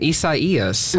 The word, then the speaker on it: Isaias: Tropical storm Isaias. – I'm going to leave that to you. – Formed overnight, yes. It's not pronounced the way that it is spelled out,